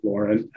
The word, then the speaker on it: Lorin: My name is spelled, so it's a little bit different, Lauren, L-O-R-I-N, and last name Culver, C-U-L-V is Victor, E-R.